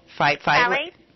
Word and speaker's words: phthalates: Okay, so you're saying that like the methylparabens too, because we've talked about, do you say that like how do you say that word? – Phthalates. – Phthalates.